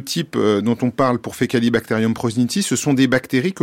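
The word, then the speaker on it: Faecalibacterium prausnitzii: Vous travaillez notamment sur Fecalibacterium prosnitzii.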